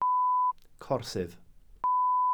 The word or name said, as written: corsydd